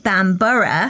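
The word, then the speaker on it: Bamburgh: that's A-L-N-W-I-C-K, is actually pronounced Anik. – Yes. – And that Bamber, i.e. Bamber Castle, is emphasised Bambura.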